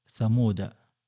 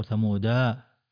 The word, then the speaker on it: ثمود: ثم دائرة مكتملة وهي لا تنطق لا وصلا ولا وقفا، أولئك ثمود، فلا تقول ثمودا،